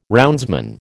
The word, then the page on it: roundsman: American English